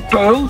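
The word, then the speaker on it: Puijl: Het is gewoon Puil, maar het wordt dus U-lange-ei wordt het geschreven.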